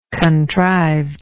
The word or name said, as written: contrived